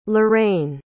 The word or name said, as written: larraine